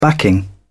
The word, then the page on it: backing: British English